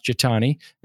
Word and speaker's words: Jethani: It looks like Jathani, but it's pronounced Jatani.